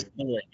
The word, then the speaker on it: Beloit: Is that how you pronounce it, Coach? Beloit? – Yes, Beloit. Yep.